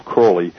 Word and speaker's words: Crowley: His name's frequently called Crowley, but he actually pronounced it himself Crowley, which is fine.